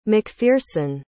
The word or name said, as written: mcpherson